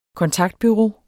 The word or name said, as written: kontaktbureau